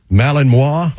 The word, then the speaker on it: Malinois: Malinois.